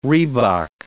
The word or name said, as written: rhebok